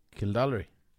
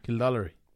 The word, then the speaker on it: Kildorrery: Yeah, there's no L. – Yeah. – Everybody that I know... – Kildallery. – ...pronounces it with an L. – Okay. – And it does not have an L.